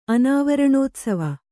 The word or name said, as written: ಅನಾವರಣೋತ್ಸವ